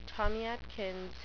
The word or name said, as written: tommy atkins